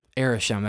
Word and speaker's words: Arishem: Arishem, that's how you say it.